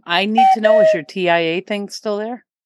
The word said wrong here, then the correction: TIE: My TIA? Why are you saying TIA? It's TIE fighter.